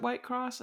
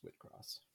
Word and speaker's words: Whitcross: I wrote Whitcross, but in the entire time I was saying it in my head, I pronounced it White Cross until like just now when I looked at it. And I was like, I'm almost 100% positive it's Whitcross. Because British.